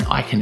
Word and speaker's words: Ikin: And Ikin is spelled I-K-I-N.